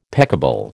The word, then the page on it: peccable: American English